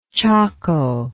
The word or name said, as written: chaco